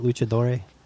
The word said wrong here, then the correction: luchador: Luchador, yeah. – Luchador. Luchadore would be feminine. Luchadoro. I don't know what I'm saying. – No, I think it's just luchador.